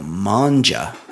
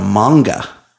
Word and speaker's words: manga: And so it's called manga, not manja.